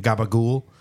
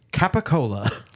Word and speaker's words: capicola: And that is how capicola, and that is how Italians pronounce capicola is gabagool, gabagool. It's just gabagool. So like pushed and mushed together that it comes out like gabagool.